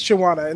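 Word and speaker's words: Chiawana: Is that how I'm pronouncing it? – Okay. It's Chawana. Yeah. – Okay, it is Chawana.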